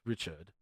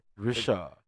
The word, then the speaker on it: Richard: Richard, do not pronounce the D as in English. Not Richard. – Okay. – Richard.